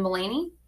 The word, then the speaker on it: Mulaney: It had, I'm probably going to say his last name wrong, but the opening was John Mulaney. – Yeah, you even pronounced it right.